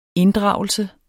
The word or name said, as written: inddragelse